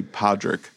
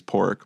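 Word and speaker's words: Pádraic: On a remote island off the coast of Ireland, Podrick, which is spelled like it would be Podrick, but they say it as Pork.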